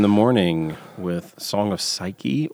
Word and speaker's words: Psyche: Is that how you pronounce it? – Psyche? Let me see. Psyche. Yeah.